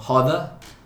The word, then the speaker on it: roda: So Roda, R-O-D-A, is how we pronounce circle in Portuguese.